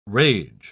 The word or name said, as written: rage